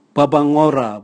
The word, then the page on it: bwabwangora: Raga